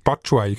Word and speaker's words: Batshuayi: Oder wie spricht man ihn aus? Batshuayi, genau, von Michi Batshuayi.